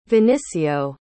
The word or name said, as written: vinicio